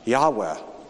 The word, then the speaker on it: Yahweh: And of course, you all probably already realize that when Lord is spelled with all caps like this, the Hebrew word is, the consonants are yod, hey, wow, hey. We think it was pronounced Yahweh.